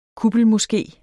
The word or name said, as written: kuppelmoské